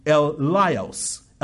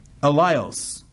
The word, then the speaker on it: eleos: Mercy, this word mercy in the Greek is eleios. It's spelled E-L-E-O-S, eleios.